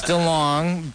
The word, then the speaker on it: DeLonge: DeLonge, did I say that right? – It's DeLonge, but okay. That's only because they started mispronouncing it in my family. It's supposed to be DeLonge or DeLonge.